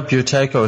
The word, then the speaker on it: Buteyko: Am I pronouncing that right? Is it Buteyko? – Yeah, Buteyko is good, yeah.